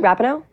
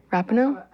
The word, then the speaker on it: Rapinoe: Rapinoe. Rapinoe.